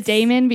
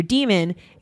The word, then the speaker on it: daemon: It's pronounced demon though. – It is pronounced demon. Well, I always said daemon because I thought it was like that.